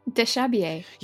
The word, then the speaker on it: déshabiller: Déshabiller.